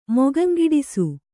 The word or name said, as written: ಮೊಗಂಗಿಡಿಸು